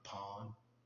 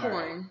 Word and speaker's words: pawn: I think it was like Hardcore Pawn, if people remember that show. – Make sure you pronounce that. Enunciate. Pawn, not porn.